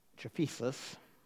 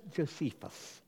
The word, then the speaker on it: Josephus: The Jewish historian Josephus, that's not how you say it, Josephus, yes.